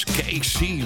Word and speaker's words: KC: Casey is met K-E-E-S-S-I-E. – Haal ik altijd door elkaar. – En dit is KC. Maar het is Engels. Dus dan spreek je het uit als Casey.